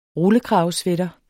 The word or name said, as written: rullekravesweater